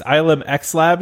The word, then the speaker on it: ILMxLAB: ILM X Lab? Is that what they, it's called? I think that's how you say it.